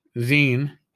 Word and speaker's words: zine: My brain wants to just spell it out. It's Z-I-N-E. That should be Zine.